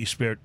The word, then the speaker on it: Gispert: It's the Gispert, which is spelled like Gispert, G-I-S-P-E-R-T. But I think it's pronounced Gispert.